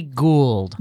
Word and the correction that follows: Tracy Gold: Gold. – Well, no, she has, like, a little symbiote in her stomach. They pronounce it Gold. – It's just Gold.